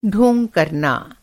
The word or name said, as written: ढोंग